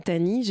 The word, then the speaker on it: Tahnee: Donc bonsoir, moi je m'appelle voilà, je m'appelle Tani, j'ai 28 ans. Alors Tani ça s'écrit euh, T A H N E E.